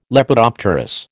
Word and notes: Lepidopterous: American English

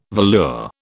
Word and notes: velour: British English

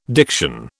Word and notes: diction: British English

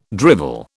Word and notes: drivel: British English

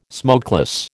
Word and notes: smokeless: American English

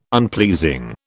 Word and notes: unpleasing: British English

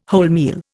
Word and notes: wholemeal: Indian

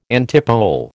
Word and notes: antipole: American English

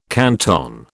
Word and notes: canton: British English

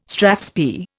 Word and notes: strathspey: Indian

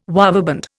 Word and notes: waveband: Indian

